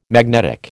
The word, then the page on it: magnetic: American English